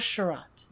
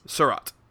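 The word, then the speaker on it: Seurat: How do you pronounce this artist's name? I was going to say George, but it's got an S at the end. Is it... – George Surratt. – George Surratt.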